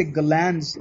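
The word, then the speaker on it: Galland: But the Arabian Nights, when it was introduced to the European world, was through, I want to say Galanz is how his name is pronounced, the translation, Golan's translation in French.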